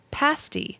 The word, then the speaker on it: pasty: Just thought I'd share that it's pronounced pasty, a short A sound, and is the key to getting it right.